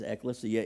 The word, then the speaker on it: ecclesia: Ecclesia is the name of the word, or some people pronounce it ecclesia, but the right way to say it is ecclesia.